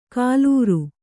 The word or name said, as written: ಕಾಲೂರು